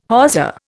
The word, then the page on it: hawser: Indian